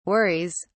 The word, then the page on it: worries: British English